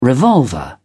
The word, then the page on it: revolver: British English